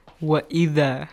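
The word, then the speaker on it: وإذا: Wa'idha. – Ahsan, wa'idha. Okay, make sure it's not too much stretched sound. Wa'idha, no. Wa'idha, two count. Alif mat.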